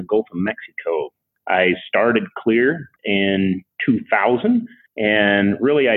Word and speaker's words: Xlear: Yes, it's spelled X-L-E-A-R and pronounced Clear because it clears your nose.